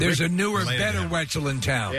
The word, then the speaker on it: Wetzel: I thought that Wetzel's Pretzels was just a silly name because Wetzel rhymes with pretzel.